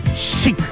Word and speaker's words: Chic: Y se pronuncia con S. Chic. Lo dicen ellos mismos en alguna de sus canciones.